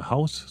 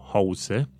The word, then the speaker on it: house: Și house cu S. Dar uh, este un alt termen, to house, adică a găzdui pe cineva, a ține pe cineva în casă, se scrie exact la fel, house, H-O-U-S-E, dar se pronunță Z, house, to house someone.